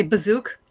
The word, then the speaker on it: Baziuk: Did I pronounce your last name correctly? – Oh, pretty. I say Bazook.